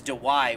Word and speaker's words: Dawai: his name is Dewey, which is weird because it's spelled D-A-W-A-I.